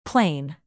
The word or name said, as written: plane